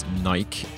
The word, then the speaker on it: Nike: And I think I just should slot in a fun bonus fact before we get started with the episode that the pronunciation of Nike in the UK, where I'm from, is Nike, and Adidas, we pronounce Adidas.